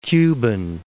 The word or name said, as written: Cuban